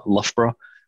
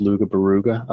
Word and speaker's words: Loughborough: Then went to university in a place in the middle of England called Loughborough, but in the US it's pronounced Luga Baruga, I think.